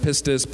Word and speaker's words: pistis: 4102 in the Greek, pronounced pistis, persuasion, conviction, reliance upon Christ.